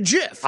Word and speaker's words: GIF: I always go GIF because graphics is supposed to be in it, so you always think hard G, right? – Oh, right. – But the guy who invented it apparently calls it the GIF.